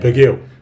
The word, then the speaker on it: Bugiel: Bugiel.